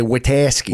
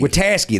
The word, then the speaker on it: Witaske: Wait, I said it right. Watsecki. – You didn't that time. – No, I did not. – Well, if you're from Chicago, you say Witaske. – Witaske, that's it. Yeah. Like Pulaski.